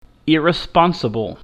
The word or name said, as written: irresponsible